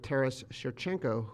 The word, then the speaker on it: Taras Shevchenko: Taras Shevchenko, who is Ukraine's national poet.